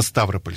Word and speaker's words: Ставрополь: Кстати, правильно говорить именно Ставрополь.